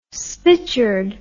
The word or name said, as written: spitchered